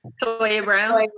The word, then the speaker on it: Cyntoia Brown: Sin, what's her name? Centona? I can't pronounce it. – Goddamn. Cyntoia Brown.